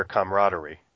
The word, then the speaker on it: camaraderie: For me, to have a camaradership, if this is the way to pronounce it. – Yeah, you could say that, or camaraderie.